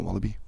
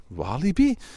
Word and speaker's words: Walibi: Wat heel bijzonder is, is, is, is de dubbele uitspraak eh, van, van het woord Walibi. Heel veel mensen zeggen Walibi, maar hier in Vlaanderen hoor ik heel veel Walibi. Walibi, ja, heel kort, Walibi of Walibi, ja, het is een, het is een verschilletje erin.